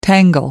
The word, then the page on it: tangle: American English